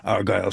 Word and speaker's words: Argyle: And she made it clear by the resoluteness of her reply that she'd been asked about Argyle socks before. they have nothing to do with the family, she said, placing heavy emphasis on the first syllable.